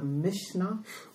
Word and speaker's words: Mishnah: and the 6 orders of the Mishnah, which is the root of the oral Torah.